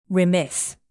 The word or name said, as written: remiss